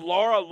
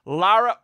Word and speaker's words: Lara: Hopefully I said that right. I've been saying Laura. Lara Overton, who covers the sidelines and covers the Colts like none other.